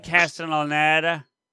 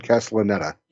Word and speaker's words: Castellaneta: Dan Castellaneta. I always say that wrong, but yeah. – You got it right. Dan Castellaneta.